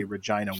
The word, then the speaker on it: Regina: But tell us about what hockey looks like in Regina, just because I wanted to say Regina once for the internet. – Yeah, it rhymes with something else.